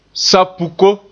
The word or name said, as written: sab-puko